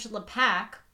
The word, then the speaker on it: LaPack: And on the screen is, like, director George LaPack rhymes with hack.